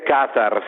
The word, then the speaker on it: Qatar: Buonasera Nicolò Casolini, appena rientrato dal Qatar. – Buonasera, che strano sentirti dire Qatar, sei l'unico, ma cioè, sei l'unico che lo dice giusto, tra l'altro.